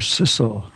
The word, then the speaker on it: Sissel: That's her name, Sissel. – How'd you spell it? – S-I-S-S-E-L.